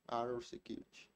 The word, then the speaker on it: Arrow Security: Se você não tem uma empresa de segurança, eu indico aí a Arrow Security. Falei certo? Arrow Security. É isso, é isso aí.